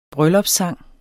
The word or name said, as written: bryllupssang